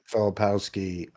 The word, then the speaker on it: Filipowski: I'm watching right now the Kyle how do you say Filipowski? – Filipowski.